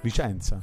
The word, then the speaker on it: Vicenza: Che tra l'altro Vizenza non si dice. – Vicenza. – È più una roba da Emiliano.